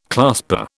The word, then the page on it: clasper: British English